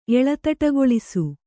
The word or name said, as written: ಎಳತಟಗೊಳಿಸು